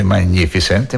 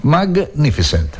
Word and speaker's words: Magnificent: Ricordatevi che in inglese il suono gni non esiste. Quindi quando trovate Magnificent non si legge Magnificent, ma sono due lettere separate. La G e la N si leggono separate, semplicissimo. Magnificent, Magnificent Radio, thank you very much.